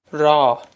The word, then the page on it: Rá: Raw